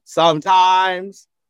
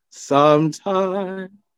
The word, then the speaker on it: sometimes: And when singers sing that, that word, if they say, sometimes I feel, the first syllable is going to get a little more. It's not sometimes, it's sometimes. As pianists, we should make the piano sing like a singer.